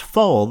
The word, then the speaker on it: Phoel: Jake's last name is spelled P-H-O-E-L, but it's pronounced Foll. The P-H is pronounced like an F, just like in the word phone.